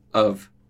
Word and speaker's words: of: Off contra of. Lembrando lá da diferença de pronúncia. Com dois Fs, off, com um F só, of. Quase como um V ali.